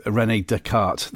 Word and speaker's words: René Descartes: I will say that Rhubarb Tart rhymes with René Descartes.